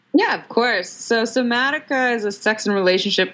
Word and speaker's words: Somatica: Somatica and Haikomi methods? Yeah, it was almost perfect. Somatica was perfect.